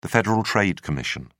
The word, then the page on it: federal trade commission: British English